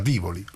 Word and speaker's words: Tivoli: E non solo si chiama Tivoli, si pronuncia Tivoli. È scritto Tivoli e si pronuncia Tivoli, cose che in Scandinavia è impossibile leggere.